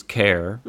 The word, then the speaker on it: Cahir: The name of the town was Care, which is, for those of you abroad and not Irish, would expect it to be spelled a completely different way. But it's spelled C-A-H-I-R.